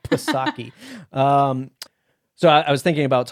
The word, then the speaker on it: Psaki: And he said to me, do you know how to pronounce the White House press secretary's last name? And I said, yeah, Psaki, Psaki. And he goes, oh, I didn't know that because I just read about it.